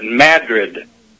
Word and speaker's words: Madrid: Well, down in Arkansas here, we pronounce the word Madrid. We pronounce it Madrid.